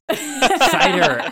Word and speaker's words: Cider: But we've also got Chad Cedar. I think that's how you pronounce his last name. Hey, Chad. – So close. Cider. – Cider. Oh, geez. Okay, so it's easy to remember. It's like apple cider.